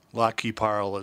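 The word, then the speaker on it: Lac qui Parle: La Qui Parle. – La Qui Parle is how they pronounce it in Minnesota.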